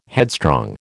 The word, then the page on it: headstrong: American English